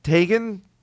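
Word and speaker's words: Teigen: So then this Sports Illustrated model, Christine Tagan, do you know? Did I pronounce that correctly? – Yes.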